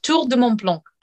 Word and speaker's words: Tour de Mont Blanc: I do not do justice to the Tour de Mont Blanc. You pronounce it much better. Say it one more time for us. – Tour de Mont Blanc.